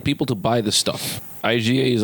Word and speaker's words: Iga: Is it Iga? Iga? – IGA, like the supermarket.